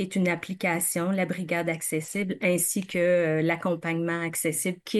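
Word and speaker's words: Axécible: Et vous êtes la conceptrice de la solution accessible. C'est bien comme ça qu'il faut prononcer parce que ça s'écrit AXECIBLE.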